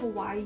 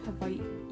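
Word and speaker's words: Hawaii (Hawaiʻi): When you say Hawaii, there's an okina between the two I's, so it's not like Hawaii. – My sister dragged me into this, help me. – And W's are pronounced as V, so it's like Hawaii, because there's okina.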